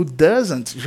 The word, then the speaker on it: doesn't: E só para dar uma recapitulada na pronúncia, a pronúncia é doesn't. É quase como se você estivesse falando o número 12, um pouco mais aberto, the, né? Doesn't, e fizesse um zant no final, doesn't. Essa é a pronúncia.